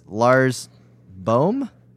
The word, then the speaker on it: Boom: Lars Boom is pronounced with a very long oo, as in bore for phone, and not as the as in the boom boom boom, and so on. So that's Lars Boom.